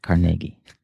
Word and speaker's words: Carnegie: And I pronounce it the correct way, Carnegie.